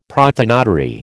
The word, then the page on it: prothonotary: American English